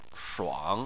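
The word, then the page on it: shuang3: tone 3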